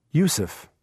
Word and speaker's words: Youssef: Yusuf. – Yusuf? How do you spell that? – Y-O-U-S-S-E-F. – Y-O-U-S-S-E-F.